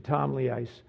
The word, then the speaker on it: Ptolemais: And when we had finished our voyage from Tyre, we came to Ptolemais, or Ptolemais is actually the pronunciation, Ptolemais, greeted the brethren and stayed with them 1 day.